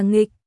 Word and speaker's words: nghịch: Cái gì mà Tống Linh? Chữ này đọc là nghịch, cái này gọi là Ngô Đạo Nghịch Linh.